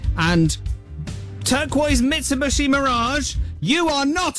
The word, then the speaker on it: Mitsubishi: And then the car behind me, a turquoise Mitsubishi Mirage, or as I pronounced earlier, Mitsubishi. – I've got to call out my own mispronunciation. – Mitsubishi.